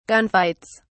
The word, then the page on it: gunfights: British English